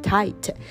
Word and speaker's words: tight: Then he pulled it tight 是指紧的 T-I-G-H 不发音，然后 T 发 T tight, 他就把这个拉紧，把这个绳结拉紧 and threw the end to the princess, 然后就把另外一端另外一个末端呢丢给公主。